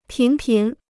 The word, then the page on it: 频频: pín pín